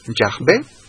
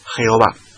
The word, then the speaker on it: Yahvé: Y repetimos, no sabemos a certeza del 100% cómo pronunciar YHWH, pero lo más probable sea usar la pronunciación Yahvé, pero que es claro es que nunca, nunca, nunca pronunciar Jehová, nunca.